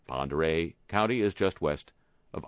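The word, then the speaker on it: Pend Oreille: Dear Harry, Pondere is pronounced Pondere.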